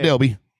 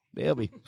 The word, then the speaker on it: Delba: I grew up in Delba, D-E-L-B-A. Call it Delby. – Delby.